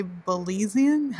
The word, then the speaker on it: Belizean: And I don't know how to say Belizean. Is that how you say it? – Yes. – Okay.